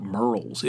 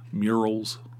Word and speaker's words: Murrells: Oh, one day we went to Murrells Inlet, or is, it, it, everybody pronounces it Merle's, M U R R E L L S, I think. Merle's, Murals. Tony says murals. I said, you have to you have to pronounce it the way the locals pronounce it.